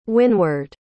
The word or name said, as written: wynward